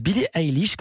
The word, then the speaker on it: Billie Eilish: C'est votre journal de la culture qui débute avec la chanteuse Billie Eilish, comme ça qu'on prononce. – Oui, c'est comme ça.